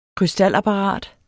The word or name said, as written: krystalapparat